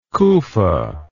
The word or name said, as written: kufa